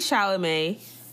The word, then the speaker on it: Chalamet: I have Timothy Chalamet, is that how you pronounce his name? – Yeah.